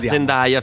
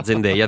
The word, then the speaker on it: Zendaya: Che adesso lo pronunciamo giusto, quindi sì, da Zendaya fino a una settimana fa, Zendaya adesso, che abbiamo capito anche noi.